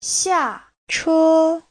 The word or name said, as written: xia4che1